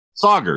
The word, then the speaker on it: Sauger: Sauger, Sauger, Sauger, Sauger. Yeah, When he first got hired, they're like, think of soccer when you say his name.